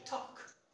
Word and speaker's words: talk: I don't know why, there's no rule for this, but sometimes the L is silent, okay? You have to write it, but when it comes to pronunciation, you should not pronounce it, because the L is mute, is silent, okay? So you have to say talk. It is not talk or walk.